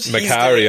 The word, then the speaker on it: Macari: You also see Lauren Ridloff's character, Macari? Macari, is that how you pronounce it? – Macari, I always pronounce it. I've only ever seen it written down.